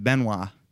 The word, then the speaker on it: Benoit: You know, Canadian wrestlers pronounce it Benoit. Benoit. – Benoit? – Benoit. – Benoit? – Benoit? Yeah, listen to Jericho's podcast. That's how he pronounces it.